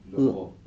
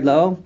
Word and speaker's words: lo'o: Ki l'soter lo'o. How do you pronounce that word, by the way? – Lo'o. – Lo'o, right? Lo'o? – Lo'o. – Okay. Lo'o.